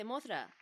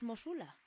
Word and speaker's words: Mosula: No se dice Mothra, su nombre correcto es Mosula.